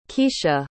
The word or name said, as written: keisha